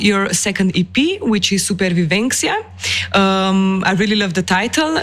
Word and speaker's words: supervivencia: yes, supervivencia with the C in Spanish is how you say it before survival.